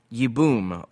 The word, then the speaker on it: Yibum: Yibum, Y-I-B-U-M.